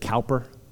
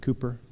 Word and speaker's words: Cowper: It looks like Cowper, but it's pronounced Cooper, C-O-W-P-E-R.